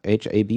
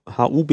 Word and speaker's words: openHAB: Und dann gibt es, was habe ich gelesen, OpenHAB oder HAB, ich weiß nicht, wie man es korrekterweise... – Ich glaube, es wird OpenHAB ausgesprochen, also OpenHAB. Mit HAB, also nicht HUB, wie man es eigentlich so meinte.